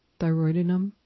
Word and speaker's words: thyroidinum: But I noticed that some people use Lophophytum Leandri in concert with the homeopathic version of thyroid, which is thyroidinum. Is that how you pronounce that? Thyroidinum? – Yes. – Yes. It is thyroidinum.